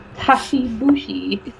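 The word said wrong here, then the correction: Kishi Bashi: Wait, is that how you say it? – Kishibashi.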